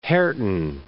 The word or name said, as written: Herten